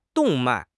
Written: 动脉

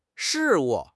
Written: 事务

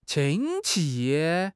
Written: qin1 qi5

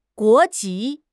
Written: guo2 ji2

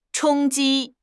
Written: 冲击

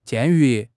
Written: 鉴于

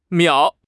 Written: miao3

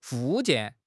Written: 附件

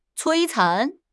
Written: 摧残

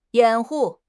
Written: 掩护

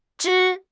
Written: 枝

